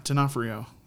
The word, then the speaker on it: D'Onofrio: No, how do you say his name? – Yes. Yeah, D'Onofrio. D'Onofrio.